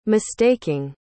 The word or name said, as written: mistaking